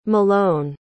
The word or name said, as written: malone